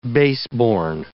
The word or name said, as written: baseborn